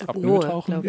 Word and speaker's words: Apnoe-Tauchen: was Apnoe-Tauchen. Spricht man das so aus? – Apnoe-Tauchen? ja. Oder? Ich würde.